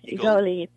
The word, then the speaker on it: Egoli: Yeah, well, now you were on a show called, and I apologize if I pronounce this wrong, is it Egoli? – Yes, Egoli, Place of Gold.